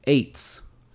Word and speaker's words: Aytes: And my masota of the week is Rochelle Aites. I think that's how you pronounce her last name. A-Y-T-E-S. Rochelle Aites. R-O-C-H-E-L-L-E.